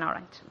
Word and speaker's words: orange: It has never taken me 5 hours to squeeze an orange. – An orange. – Orange. – Okay, be careful, okay? Because orange is spelt with an A, but we pronounce it as if it were an I, okay?